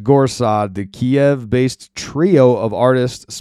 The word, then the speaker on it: Gorsad: Jake Shields got it wrong. It's not Gorad Kiev. It's Gorsad Kiev is how you spell it. G-O-R-S-A-D.